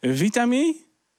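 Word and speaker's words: Witamy: En dan moet ik het goed uitspreken. Vitamie. Ja, de dames achter de balie knikken inderdaad. Dat is in het Pools welkom.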